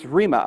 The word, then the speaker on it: rhema: And the word here is rhema, R-H-E-M-A, rhema. E has a long vowel sound, rhema.